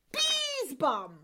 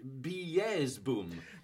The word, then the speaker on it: Beesbum: It's pronounced Beesbum. – But it's Dutch, surely. Beesboom.